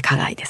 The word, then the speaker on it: 花街: これは花街と書いて花街ですね、これの、イベントがあるということなんですが、ちょっとご紹介していただいていいですか。